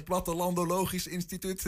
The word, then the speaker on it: Plattelandologisch Instituut: Die uitspraak is zo ingewikkeld. – Plattelandologisch Instituut. – Plattelandelogisch Instituut. Plattelandologisch Instituut. Drie keer oefenen en je hebt oh, het.